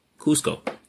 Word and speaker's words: Cusco: Cusco.